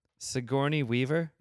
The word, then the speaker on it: Sigourney Weaver: Sigourney Weaver? – Mm-hmm.